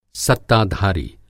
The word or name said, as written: सत्ताधारी